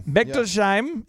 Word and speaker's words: Bechtolsheim: Do you want to try to pronounce this last name here? Andy Beck Bechtolsheim. Bechtolsheim. – Bechtolsheim. Yeah.